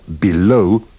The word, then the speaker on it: below: In these dialogues, remember to pronounce the first E of below, beneath, beside, and between with the sound of the I in sit.